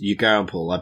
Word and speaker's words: Yugambeh: It's Yugambeh. I've been pronouncing it wrong, so I keep trying to remember to pronounce it correctly.